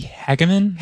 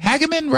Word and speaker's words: Hageman: Now, what he's talking about in favor of is something called the Stop Climate Shakedowns Act from Harriet Hageman, or how do we say it, from Wyoming? – I think it might be Hageman. – Hageman, right? – Yeah.